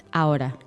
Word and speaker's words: ahora: Now there's an H in this word. – Yes, but you don't pronounce it. – Okay. – So it's ahora.